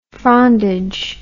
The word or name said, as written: frondage